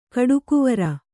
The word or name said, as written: ಕೊಡುಗೈವೀರ